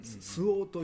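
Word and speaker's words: 蘇芳: スオウという色があるんですか、うんうん、ソホ、どんな阿蘇山のソにね、よしで、うんうん、スオウと読むんですけども、うんうん、それをまあ、あえてね、うん、ややこしいんで、うん、ソホと読んだり、うん、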